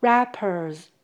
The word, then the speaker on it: wrappers: 所以从这个情境上下文来看，wrappers 在这里是指水饺皮，加 s 是复数形，请留意 w 是不发音的。 Wrappers。